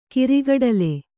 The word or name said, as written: ಗಿರಿಗಿಟ್ಟಲೆ